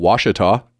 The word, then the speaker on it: Ouachita: Now, you might notice I'm pronouncing Ouachita differently than I did last week and every other time I've ever pronounced this Indian word.